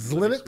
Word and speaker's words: Xilinx: Like Xilinx with an X at the front? – Yeah, it's like Linux with an X in front of it.